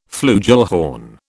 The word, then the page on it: flugelhorn: British English